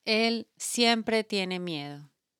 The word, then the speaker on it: miedo: Miedo. – How do you spell that? – M-I-E-D-O.